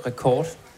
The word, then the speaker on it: Records: Hvordan skal det siges? – Vi udtalte det rekord. – Okay. – Men det er godt at få sagt det en gang for alle. – En fordanskning af rekord.